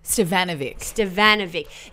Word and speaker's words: Stefanovic: What you can expect this week is a really interesting chat with Sofia Stefanovic. – Stevanovic. – She's like, has the Serbian version, like she pronounced the Serbian version of Karl Stefanovic.